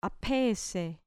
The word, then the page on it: aphesei: grec